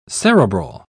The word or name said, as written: cerebral